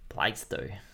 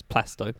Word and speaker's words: Plasto: Plaisto. – No, it's Plasto, but yeah, Plasto. So just remove the I. Yeah, just it trips everybody up.